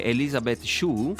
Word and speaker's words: Elizabeth Shue: E in seguito, e l'altra protagonista invece è Elizabeth Shue, Sì. Si pronuncia così? – Sì, giusto.